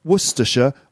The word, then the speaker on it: Worcestershire: So Worcestershire looks like Worcestershire. It's actually pronounced Worcestershire or even Worcester in some cases.